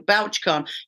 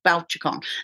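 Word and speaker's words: Bouchercon: And that's how come George and I ended up going to BouchCon. You pronounced it wrong, by the way. It's not BouchCon. It's BouchCon.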